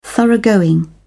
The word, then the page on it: thoroughgoing: British English